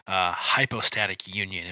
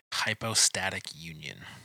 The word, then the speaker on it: hypostatic union: But those are the two main ones that came to my mind as when I thought about early church issues with this hypostatic union, if we can use a fancy theological term. Now I'm going to look that up to make sure I said it right. Hypostatic union. Yes.